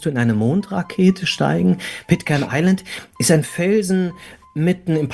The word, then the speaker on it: Pitcairn Island: Von Pitcairn Island, wenn ich es richtig ausspreche. – Pitcairn Island, ja.